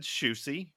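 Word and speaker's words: Siouxsie: The band then set out on a UK tour as an opening act for Sushi and the Banshees. And did you just say sushi? – No, I said Shoosie.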